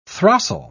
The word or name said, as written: throstle